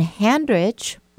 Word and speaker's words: Handrich: My handle for Facebook, just go under my name, which is Debbie, D-E-B-B-Y. Debbie is spelled D-E-B-B-Y. And Handrich, my last name, is just like it sounds. H-A-N-D-R-I-C-H.